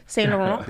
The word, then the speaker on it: Saint Laurent: Saint Laurent.